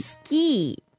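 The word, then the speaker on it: ski (スキー): So it's ski with a long I at the end.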